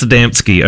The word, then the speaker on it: Adamski: How do you pronounce Adamski. It? It's pronounced Adamski. Okay, yes, good.